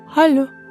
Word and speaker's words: Hallo: Hallo. Pisze się hallo. – H-A-L-L-O. Aha, czyli to się tylko jedną literką różni od hello angielskiego.